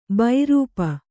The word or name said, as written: ಬೈರೂಪ